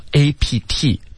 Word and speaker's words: apt: 嗯，这个词呢，在我们的经济类的这个文章当中，你经常会看到 APT，表示的就是做什么有倾向，符合适合怎么怎么样的。 – 这个单词的读音呢是 apt。